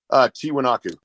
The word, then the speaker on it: Tiwanaku: Tiwanaku.